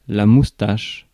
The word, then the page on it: moustache: France; [mus.taʃ]